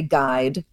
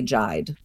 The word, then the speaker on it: guide: When we say guide, the only thing the U is doing is that diacritical role of making us certain that we shouldn't say jide, right? We say guide, we don't say jide.